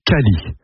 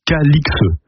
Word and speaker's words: Calix: J'ouvre ici une parenthèse, le nom était autrefois prononcé Cally, comme le montrent diverses formes anciennes du XIIIe au XVIIIe siècle, mais l'orthographe aberrante Calix a entraîné la prononciation actuelle Calixe. Voilà pourquoi nous prononçons Calix depuis plus de 300 ans.